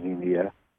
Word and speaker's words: Xenia: This is from D in Xenia, I think it's pronounced Xenia. It's actually X-E-N-I-A, Ohio.